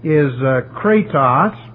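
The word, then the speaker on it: kratos (κράτος): The first word is kratos, if I can spell it right here, K-R-A-T-O-S, that means manifested power.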